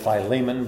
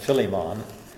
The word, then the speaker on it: Philemon: Colossians and Ephesians and Philippians, and we call it Philemon, but it's actually pronounced Philemon.